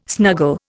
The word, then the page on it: snuggle: Indian